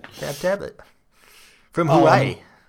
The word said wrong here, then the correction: Huawei: How do you pronounce it? Is it Hawaii? It looks like Hawaii. I'm just going to pronounce it Hawaii. – No. It's Huawei.